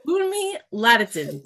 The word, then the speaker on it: Bunmi Laditan: Bunmi Laditan.